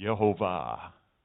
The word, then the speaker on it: Yehovah: I'm going to use his name, which recent research has pretty much proven the pronunciation is Yehovah. Okay, they've now found over 1,500 ancient manuscripts that have all the vowel points and show it that way.